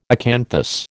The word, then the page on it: acanthus: American English